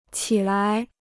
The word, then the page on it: 起来: qi lai